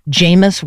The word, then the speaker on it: Jameis: When we come back, we're going to talk about Jameis. Am I saying that right? It's got a lot of vowels in there. Jameis Winston. That's it.